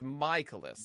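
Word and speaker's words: Mikolas: Miles Michaelis. Is it Michaelis? – It's Michaelis. – Michaelis? – Miles Michaelis.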